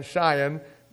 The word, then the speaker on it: Sion: It's spelled S-I-O-N, Zion, but it's Zion.